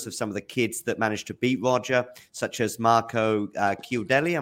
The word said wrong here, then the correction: Chiudinelli: Am I saying that right, Simon G? – Chiudinelli. – Goodinelli, thank you.